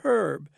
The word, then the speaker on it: herb: Well, at first it's spelled H-E-R-B. – Yes. – And so that's a herb. And however, herb is correct, because that's the old Latin and old Roman, and that's the ones that you get most of that from.